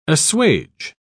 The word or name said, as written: assuage